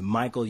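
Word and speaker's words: Micheal: Um, my Facebook name is Michael Ude, um, and it's M I C H E A L.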